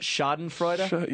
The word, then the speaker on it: Schadenfreude: That's Schadenfreude. How do you pronounce that? – Schadenfreude. – Yeah, there we go.